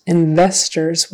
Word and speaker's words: investors: Investors with a V in the middle. Investors, even though it's spelled with an O-R. Investors.